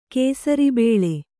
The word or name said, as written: ಕೇಸರಿಬೇಳೆ